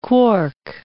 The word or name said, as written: quark